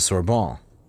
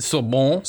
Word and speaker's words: Sorbonne: and he pursues courses at the Sorbonne. – Okay, all right. – You like how I said that? – Sorbonne. – Sorbonne. – Yeah, very nice.